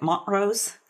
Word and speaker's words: Montrose: It's weird because people over there pronounce it Montrose, kind of like Montrose? – French. Yeah. – Oh, so they dropped the T.